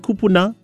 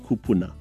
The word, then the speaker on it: kupuna: It can be commonly mispronounced, kupuna, and the pluralized form of that word, kupuna. You just elongate the u or the u in that word kupuna.